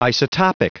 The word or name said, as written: isotopic